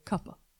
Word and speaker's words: Cooper: So then we've got the Santee Cooper issue, where I've been told by a former Goose Creekian that you say it correctly when you say Cuppa.